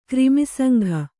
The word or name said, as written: ಕ್ರಿಮಿಸಂಘ